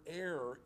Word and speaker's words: heir: And he preached a sermon on Romans 8, 15, 16, and 17, and he did not know that the word heir is not pronounced like hair.